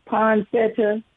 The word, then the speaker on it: poinsettia: I think you pronounce it poinsettia. – Oh yeah, poinsettia. Right. – Yes.